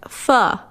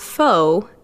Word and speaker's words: pho: Contrary to its spelling, the Vietnamese beef noodle is pronounced pho, not pho, as the dish was influenced by a French dish called pot de feu.